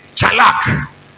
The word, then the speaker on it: tsalach: The word prosper is chalak. Somebody say chalak. Chalak is spelled T S A L A C H.